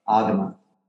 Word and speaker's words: agama: It's an agama. – How do you spell it? – Long A, gamma, G A M A, agama.